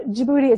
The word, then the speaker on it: Djibouti: Yep, and people can't usually spell it because it starts with a D, but you pronounce it with a J sound, so it's confusing. Yes. Um, so Djibouti is a country, and the city, the capital city, is Djibouti.